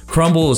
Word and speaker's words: Crumbl: Crumble is spelled C-R-U-M-B-L. And then cookies.